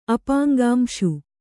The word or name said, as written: ಅಪಾಂಗಾಂಶು